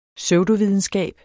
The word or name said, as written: PSEUDOVIDENSKAB